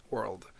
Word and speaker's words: world: Because in Spanish, there's no such thing as having three consonants, you know, after each other. It just doesn't exist. So to have a word with only one vowel, and then having RLD after the vowel was actually, even today, most Spanish speakers, they have a hard time pronouncing that word. I mean, that word, world.